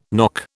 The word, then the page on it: knock: British English